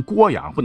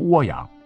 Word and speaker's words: 涡阳: 六安一直是读作陆安啊，就有点像这个亳州不能念成亳州，漩涡的那个涡你得念成郭阳，不能念成涡阳。